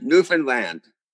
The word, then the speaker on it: Newfoundland: We try to help come from aways, or mainlanders as they are called, with the pronunciation by saying, understand Newfoundland, understand Newfoundland.